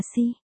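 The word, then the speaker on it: si (四): Người Trung Quốc thường kỵ số 4, vì phát âm là si. Si, nghe giống như si. Si tức là chết,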